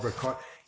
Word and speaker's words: Richard: They tend not to in Guatemala use Ricardo. That's more Mexican than some other Latin American countries. Here they try and pronounce my name like Richard, Richard. – something like that, you know.